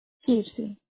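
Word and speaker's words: Kirssy: Kirsi.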